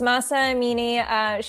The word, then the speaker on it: Mahsa Amini: Mahsa Amini? Am I saying her name correctly? – I believe she was. Mahsa Amini, she was a Kurdish-Iranian woman.